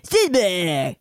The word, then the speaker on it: Strasbourg: Well, it's spelled Strasbourg. – So then that's French. – Strasbourg. – Strasbourg. That's how you pronounce it in French. – Strasbourg.